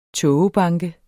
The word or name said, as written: tågebanke